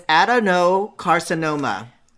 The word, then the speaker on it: adenocarcinoma: It's adenocarcinoma.